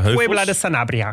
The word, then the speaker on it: Puebla de Sanabria: van Mos eh, naar Puebla de Sanabria gingen we.